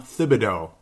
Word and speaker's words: Thibodeau: Now, ultimately, the whole conversation was, the Minnesota Timberwolves, they had a Tom Thibodeau, now that I'm saying it right, Timberwolves Explosion.